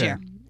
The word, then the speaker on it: Niger: I'm using the sort of more French African pronunciation. – Okay. – Niger.